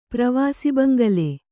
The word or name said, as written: ಪ್ರವಾಸಿಬಂಗಲೆ